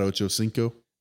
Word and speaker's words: Ochocinco: I'm going to butcher the shit out of Chad Ochocinco. – Ochocinco, yes.